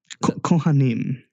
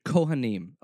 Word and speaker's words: Kohanim: You were saying that you were the Kohalim. – Kohanim. – Kohanim. Okay. Yeah.